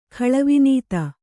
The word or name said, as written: ಖಳವಿನೀತ